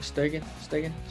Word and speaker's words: Stegen: They also got Marc-Andre Sturgeon, I think that's how you say it. Stegen. Okay.